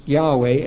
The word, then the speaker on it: Yahweh: And then their footnote under Jehovah, footnote C, says, properly the name should be pronounced Yahweh as it is spelled in many modern versions.